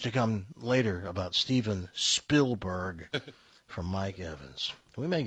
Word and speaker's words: Spielberg: That was Mike Evans, who, by the way, still doesn't know how to pronounce Spielberg's last name. He calls him Steven Spielberg. – Yeah. – He always does that. It's Spielberg, not Spielberg.